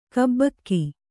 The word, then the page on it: ಗೆಬ್ಬುಗೆ: Kannada